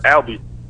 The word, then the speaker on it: Albie: Yeah, no, this is Albie. My name is Albie, A-L-B-I-E.